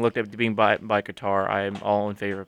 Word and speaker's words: Qatar: Quater. United States, otherwise known as Circuit of the Americas, and Interlagos down in Brazil. – Quater. – How do you say it? – Qatar. – Qatar.